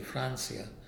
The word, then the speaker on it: Francia: You know, I've thought of that, and we pronounce it, my family pronounces it the Spanish way, Francia.